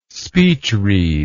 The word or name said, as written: speechread